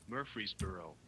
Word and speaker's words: Murfreesboro: Murfreesboro. Murfreesboro. Oh, like the person's name was Murphy.